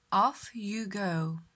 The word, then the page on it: off you go: slow